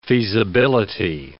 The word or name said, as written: feasibility